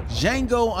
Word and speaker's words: Django: Django. The D is silent. Django Unchained, 2012 American Revisionist Western.